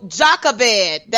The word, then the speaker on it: Jochebed: All right, so Jochebed, that's what it, how you pronounce it. And so Jochebed is the mother of Aaron, Miriam and Moses, just so everybody knows. She is the daughter of a Levi. So just wanted to let you know that. I did say it right, Jochebed.